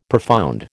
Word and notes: profound: American English

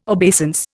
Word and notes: obeisance: Indian

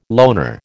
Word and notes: loner: American English

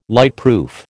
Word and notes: lightproof: American English

